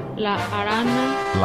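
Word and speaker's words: La Araña: Is that how you pronounce it? El Arana? – La Arana. – La Arana. Okay.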